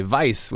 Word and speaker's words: Weiss: Instead of the W for the Weiss, you say Weiss with a V. – Your German pronunciation is excellent. – That's how the Germans say it.